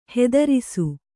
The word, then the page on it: ಹೆದರಿಸು: Kannada